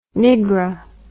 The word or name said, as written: niggra